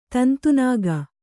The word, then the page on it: ತಂತುನಾಗ: tantu nāga